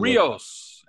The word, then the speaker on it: Rio: You Rios. – Rios. Of course, Rio Grande. – And you're supposed – yeah, the Rio Grande. Yeah, you're supposed to pronounce it as Rio.